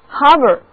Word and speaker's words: harbour: 另外还有一个单词也表示港口，它就是 harbor， H-A-R-B-O-U-R，字母组合 A-R 读作长长的 R， harbor，它的意思是海港或者港口。